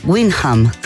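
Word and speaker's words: Wyndham: Y ahí de frente se vino después para el Hotel Cumbres de acá, después ahora está en el Hotel Malham, nunca lo pronuncio bien. Windham.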